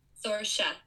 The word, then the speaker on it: Sorsha: This book, it's got this haunting, like, atmosphere, or ominous atmosphere of, I can't pronounce the name of the island. – Oh, Sorsha. – Sorsha, thank you.